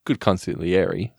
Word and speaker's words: consigliere: I mean, good consigliere. – Oh, that was the right way to say it. You got to have it the E at the end. Yeah. Consigliere.